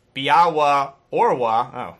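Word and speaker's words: Białe Orły: Now, he also says, on a side note, I think you were a little hard on the national team's nickname, the Biale Orli, which is pronounced Biawa Orwa, since the I with a slash through it makes the W sound, kind of like the W in water.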